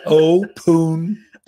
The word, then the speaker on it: Opuntia: Apuntia? Apuntia. – Okay. – Yeah. Or opuntia. Or apuntia. It's opuntia.